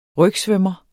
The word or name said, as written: rygsvømmer